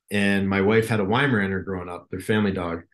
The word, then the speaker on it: Weimaraner: How do you pronounce the breed? – Weimaraner.